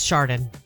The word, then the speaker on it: Chardon: It's Chardon, I promise.